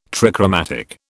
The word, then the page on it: trichromatic: British English